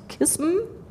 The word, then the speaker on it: KISM: Bei der Berliner Agentur für Elektromobilität und dort unter anderem zuständig für das Projekt, wie spricht man es aus, KISM? – Gar nicht schlecht.